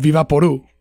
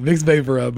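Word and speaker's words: VapoRub: Let me tell you, my grandma, it's called Viva Poru. Is what they call it. Vicks Vapora, but she can't pronounce it right. It says Viva Poru.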